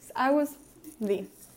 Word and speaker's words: Agust D: Se escribe como está en la camisa. A-G-U-S-T-D. Eh, espacio D, no junto D. Agus D.